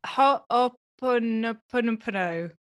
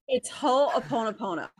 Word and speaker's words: Ho'oponopono: is the Ho'oponopono. That was wrong, but... – it's Ho'oponopono.